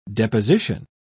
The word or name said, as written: deposition